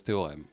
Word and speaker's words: Theorem: Alors, je vais le prononcer à la française, théorème, parce que je pense que ça se dit theorem, je sais pas quoi.